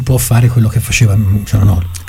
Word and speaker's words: Çalhanoğlu: La G è muta, Çalhanoğlu. – Çalhanoğlu. – In turco la G con il taglietto non si pronuncia.